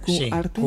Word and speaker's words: Q-Arte: Oye, lo he dicho bien, lo de Q-Arte, se dice así. – Sí, está bien dicho. – Hay que hacer una breve pausa. – Q-Arte. Sí, Q-Arte, efectivamente.